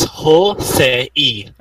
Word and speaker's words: Hosei: So why is it Jose University? – It's Jose E. – Okay. – Because Ho is one character, Se is another character, E is another one.